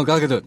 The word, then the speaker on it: Gal Gadot: No, Gal Gadot. That's how you say it. – Is it Gadot? Gadot. I mean, the American hosts pronounce it Gadot. – They're wrong. Yeah. She clarified this on Jimmy Kimmel.